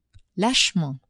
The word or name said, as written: lachement